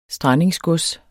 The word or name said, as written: strandingsgods